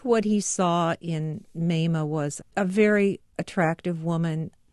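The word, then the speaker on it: Mamah: Mema is a nickname for Martha and is pronounced Mema. It's a name that puzzles when first encountered. People ask, is that Mama, like Mother? – Mema was a mama.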